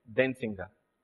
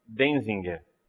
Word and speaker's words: Denzinger: Em alemão, a pronúncia correta seria Denzinger. Né? Mas vamos aportuguesar e chamar de Denzinger.